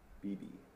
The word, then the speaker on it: Beebe: I don't know how to pronounce his name. Dion Beebe. – Beebe. – Beebe. Okay. I'll just say Beebe.